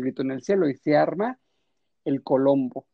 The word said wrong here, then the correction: quilombo: El Quilombo.